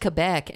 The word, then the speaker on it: Quebec: Fuck that, it's technically pronounced Quebec, and it's also technically pronounced poutine.